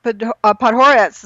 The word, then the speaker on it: Podhoretz: And New York Post John Podhoretz, is that how you say his name? – Yep. Yes.